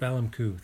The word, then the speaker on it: Balamku: I like Balakmul. Did I say that right? – Balamku? – Balamku. Thank you.